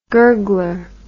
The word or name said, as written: gurgler